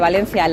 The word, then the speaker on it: Valencia: Siempre he dicho que considero que fonéticamente Valencia, la E, se pronuncia de forma cerrada y no de forma abierta,